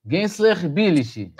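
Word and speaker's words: Gençlerbirliği: Gensler Birlig. Gensler Birlig, é assim que fala.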